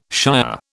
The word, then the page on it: shier: British English